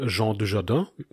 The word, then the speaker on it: Jean Dujardin: Der erste Film ist ein französischer Film, November ähm, von 2022, mit der Hauptrolle mit Jean Dujardin. Ich glaube, ja, das ist richtig ausgesprochen. – Das genau. ist richtig, ja.